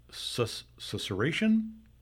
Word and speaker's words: susurration: I love the word susurration. – Mm-hmm. – A whispering sound like silk slippers on a hard floor. Is that susurration? Is that right? Is that how you say it? – Yes. – Susurration.